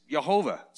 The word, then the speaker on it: Yehovah: The first one is the Hebrew word, and it's Yehovah. It's actually spelled YHVH. We've added the vowels to make it something we can pronounce. But it's Yehovah.